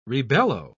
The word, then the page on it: rebellow: British English